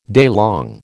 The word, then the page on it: daylong: American English